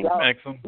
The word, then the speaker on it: Exum: Exum, yeah.